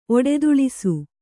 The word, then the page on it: ಒಡೆದುಳಿಸು: Kannada